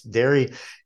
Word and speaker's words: Darë: Dairy is a vegan cheese company. It's spelled D-A-R-E, and there's an umlaut above the E, and it's pronounced dairy.